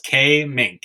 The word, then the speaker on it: Kaemingk: Well, Dr. Matt Kaemingk, we never established how to say your last name. Is it Kaemingk? – It's Kaemingk. Yeah, Kaemingk.